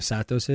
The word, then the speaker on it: Satos: Do I know what the meaning of Satos is? – Oh, that's how you say it.